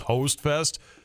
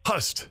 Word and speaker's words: Hostfest: I know it's spelled like Hostfest, and I knew it wasn't pronounced Hostfest, but I was kind of like Hostfest is what they tell me now. – So it's not even hot. – No. Hust.